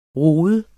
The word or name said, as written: rod